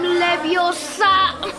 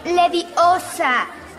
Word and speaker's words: Leviosa: ¡Wingardium Leviosa! No, espera. Le sacarás un ojo a alguien. Además, no se dice así. Es Leviosa, no Leviosa.